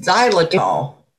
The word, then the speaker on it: xylitol: So xylitol. – Thank you. – All right. Now we know how to say it.